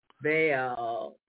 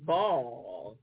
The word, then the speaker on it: Baal: He says there are two ways to say this word. Either Baal. You can say Baal. And that's how Reverend Price pronounced it. Baal. – Mm-hmm. – So he said it's either Baal or Ball.